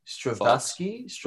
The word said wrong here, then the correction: Straczynski: J. Michael Straczynski. – J. Michael Straczynski. There we go.